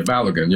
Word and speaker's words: Balogun: I don't know if I say his name right. Balogun. – Yeah, Balogun, yeah.